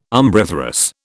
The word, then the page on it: umbriferous: British English